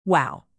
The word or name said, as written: wow